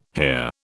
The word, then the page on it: here: British English